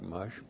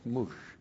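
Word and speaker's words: mush (מוּשׁ): M long U S H. See, I told you it looked like mush, but it's mush.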